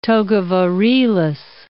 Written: toga virilis

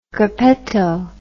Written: gruppetto

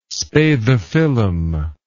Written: spathiphyllum

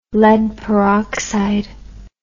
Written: lead peroxide

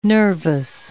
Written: nervous

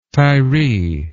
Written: tyree

